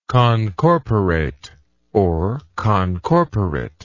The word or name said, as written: concorporation